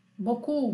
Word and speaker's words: beaucoup: Então aqui na verdade, a, a forma correta de escrever boku, que significa muito, é B-E-A-U C-O-U-P.